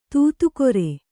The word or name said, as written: ದಾದಾಗಿರಿ